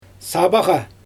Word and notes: sabaGa: Xyzyl

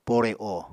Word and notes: pore o: apatani

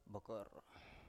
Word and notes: bokor: Biak

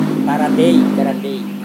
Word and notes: tarandei: Serewen

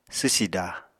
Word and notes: swsida: apatani

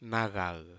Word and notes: malal: Aneityum

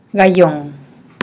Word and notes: gayon: Teotitlán del Valle Zapotec

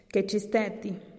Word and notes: kechisteti: TdVZ

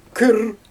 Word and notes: küür: Xyzyl

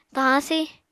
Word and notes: tahañ-si: Apatani

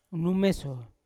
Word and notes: kunumeso: Yawa

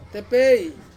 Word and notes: tepei: Ansus

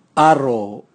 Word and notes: aro: Raga

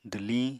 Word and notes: dwliñ: apatani

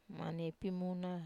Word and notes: maniai pimuna: Wamesa

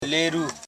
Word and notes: leru: le.ru

